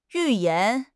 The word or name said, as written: 预言